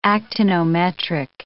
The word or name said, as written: actinometric